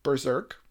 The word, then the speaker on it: BRZRKR: So Netflix is developing a live-action film and a follow-up anime series based on Keanu Reeves' Berserk. It's spelled kind of weird. I'll spell it out. B-R-Z-R-K-R. There's just no E's in it.